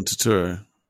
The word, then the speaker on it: Turturro: What about Carmine Falcone, played by I always get the pronunciation wrong, but John Turturro, is that right? – Well, Turturro.